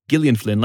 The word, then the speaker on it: Gillian: Is how you pronounce her name. Gillian Flynn, like Gillian Jacobs from Community.